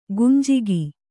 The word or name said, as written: ಕೆಂಜಗ